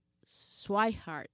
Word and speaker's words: Swihart: Está Sandy León, está Cristian Vázquez y está Blake Swihart. Lo dije bien. – Como, corazón. Como el corazón, exactamente.